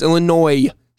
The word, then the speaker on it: Illinois: I was born in Illinois, and it's Illinois singular. There's no S.